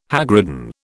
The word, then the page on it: hagridden: British English